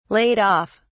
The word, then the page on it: laid-off: British English